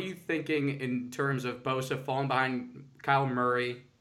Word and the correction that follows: Kyler Murray: or Kyler Murray?